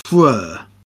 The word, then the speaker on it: Freur: So the band made the compromise that the squiggle was pronounced Freur.